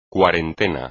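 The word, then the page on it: cuarentena: español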